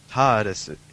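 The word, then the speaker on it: Tāresu: Well, the Japanese spelling of the English word lettuce is retasu. And if you rearrange the syllables in this, you get taresu. And that's pretty close to the way that taresu is, except that you have an extra long vowel in there after the A.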